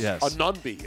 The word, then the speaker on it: Anunoby: Anunoby, it looks like. – Yeah, Anunoby. – Anunoby. I said it right. I said Anunoby.